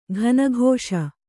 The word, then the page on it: ಕನಿಕಿಸು: kanikisu